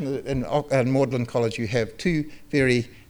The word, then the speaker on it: Magdalen: It looks like Magdalen, but they pronounce it Magdalen in Oxford University.